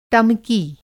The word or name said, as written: टमकी